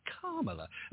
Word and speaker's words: Kamala: But I've also heard her correct people and say it's Kamala, like a comma.